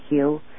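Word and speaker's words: Huế: Those who learned their lessons from history know full well what happened after Hue, H-U-E, that's pronounced, folks.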